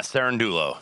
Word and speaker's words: Cerundolo: And Cerundolo, did I say it right? – Yeah, Cerundolo.